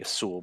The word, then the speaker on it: Azul: Well, I think the Spanish pronunciation would be Azul, but I think the Portuguese is more Z sound.